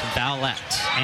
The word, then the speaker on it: Vaulet: V-A-U-L-E-T, pronounced vowel, like can I buy a vowel? And then et, Vallette.